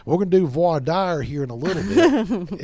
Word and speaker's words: voir dire: Well, voir dire is spelled V-O-I-R. That's the first word. – Uh-huh. – And dire is spelled D-I-R-E. – Voir dire. – It's pronounced voir. – Voir. – Dire. – Dire.